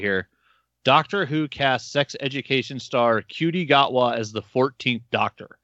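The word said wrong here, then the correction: Ncuti: S-H-O-O-T-I is the pronunciation guide for it. – S-H-O-O-T-I. Shooty. Okay. So I still didn't pronounce the N. So it's not N Cutie.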